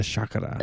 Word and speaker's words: chakra: I stay young with the chakra. – The chakras. I guess the appropriate Sanskrit pronunciation is chakra.